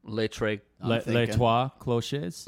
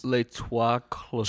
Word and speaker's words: Les Trois Cloches: How do you say it? – Think of Ménage à Trois. – Les Trois Cloches. – Les Trois Cloches.